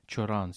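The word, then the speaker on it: Cioran: Тобто, хто такий Чоран, Сюран, і все ж таки, чому два імені, два прізвища? – Так, ну проста відповідь, чому Чоран і Сюран, тому що в румунською. Е, він е, вимовляється як чоран, і про це варто не забувати, тому що в Румунії це не просто людина, яка письменник, який народжується в Румунії.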